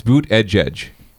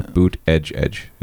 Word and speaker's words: Buttigieg: But it's Buttigieg, if you listen to his Twitter bio. Buttigieg is how you pronounce it.